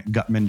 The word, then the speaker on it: Guttmann: So it's uh, Ben, B E N, Guttmann, G U T T M A N N, two T's and two N's.